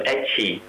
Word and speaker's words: ecchi: I think what you're talking about is adult comics, or in Japan there's another term, ecchi, which is the pronunciation for H, which means comics which are of a very sexual nature.